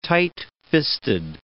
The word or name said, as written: tightfisted